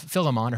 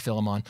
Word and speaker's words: Philemon: So, for instance, if we look at the letter of uh, of uh, of Philemon or Philemon, however you pronounce it, different scholars pronounce it different ways. I like Philemon.